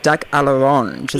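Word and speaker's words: duck à l'orange: Now, last night you're up against Ali and Peter, and you had to cook the retro 70s classic duck a l'orange. Is that how you say it? Mm-hmm. – Yeah, that's it.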